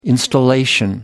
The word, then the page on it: installation: American English